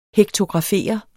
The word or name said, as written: hektografere